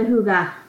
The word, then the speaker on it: hygge: Well, our witchy self-care goes with the hygge, right? Did yes. – I just say it right? – You did. Yes.